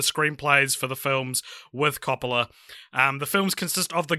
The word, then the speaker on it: Coppola: I got Coppola on my official pronunciation video that I just watched.